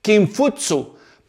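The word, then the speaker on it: Kinfutsu (孔夫子): El confucianismo debe su nombre a su fundador, Confucio, que en chino se pronunciaba más o menos así, Kinfutsu, pero para nosotros los occidentales simplemente le dijeron Confucio.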